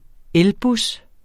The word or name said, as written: elbus